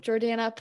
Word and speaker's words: Jordana: So, Jordana, plug. Am I saying it right too? I know you have like a New York accent, but okay. – No, you're totally saying it right. Don't worry.